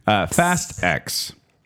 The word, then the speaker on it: Fast X: I don't know how to pronounce this. It's Fasix? Fastix? – Fasix. – Fast X.